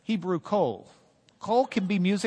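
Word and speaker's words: kol (קול): You don't pronounce the vav here. It just has an O sound. It takes a vowel sound. Then you've got the L at the end, kol.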